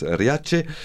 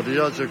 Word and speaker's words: Riace: Alors, comme beaucoup de petits villages, Rias, mais bon, je suppose que ça se prononce Riace, situé au sud de l'Italie, mourait à petit feu à cause de l'exode rural.